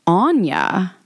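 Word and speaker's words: Áine: About Ayn, pronounced Anya.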